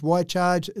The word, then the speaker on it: Wi-Charge: So we charge, why charge? It's spelled WI-charge.